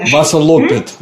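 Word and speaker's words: Vasaloppet: А, но вот это известное э, Васалапет, да, я правильно произношу? – Васалопет, лопет. – Лопет, да, да. Васалопет.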